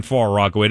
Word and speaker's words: Far Rockaway: Ellie is in Far Rockaway. Did I say that right? Ellie, want to know? – Yeah, you did.